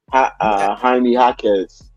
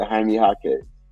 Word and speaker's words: Jaime Jaquez: Jaime Jaquez. – That's how you say his shit? – Yeah, Jaime Jaquez.